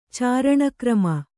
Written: ಚಾರಣಕ್ರಮ

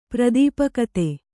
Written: ಪ್ರದೀಪಕತೆ

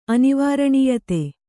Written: ಅನಿವಾರಣೀಯತೆ

